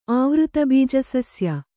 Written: ಆವೃತಬೀಜಸಸ್ಯ

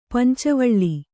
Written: ಪಂಚವಳ್ಳಿ

